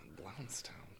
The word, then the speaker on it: Blountstown: Blountstown. – Blountstown. B-L-O-U-N-T-S town. Blountstown. I'm saying it right.